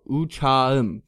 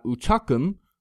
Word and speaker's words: uçağım: Uçağım. So not uçakım. Uçağım. Right, that K changes to a silent G.